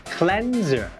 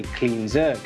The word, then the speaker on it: cleanser: คำตอบที่ถูกต้องก็คือข้อ C cleanser ครับเพราะ cleanser แปลว่าผลิตภัณฑ์ทำความสะอาดผิวและต้องออกเสียงว่า cleanser ไม่ใช่ cleanser